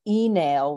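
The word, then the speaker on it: Uí Néill: And he was from a family called the Enail, which is how you would pronounce it in Old Irish, but it became the O'Neills, which is people probably much more familiar with.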